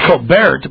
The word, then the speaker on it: Colbert: It's Colbert, but the T is silent.